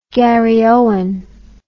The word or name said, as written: garryowen